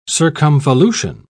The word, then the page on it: circumvolution: British English